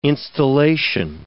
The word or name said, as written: installation